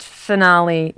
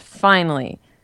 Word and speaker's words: finale: Okay, it's pronounced finale, not finally.